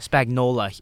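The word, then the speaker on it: Spagnuolo: Spagnola.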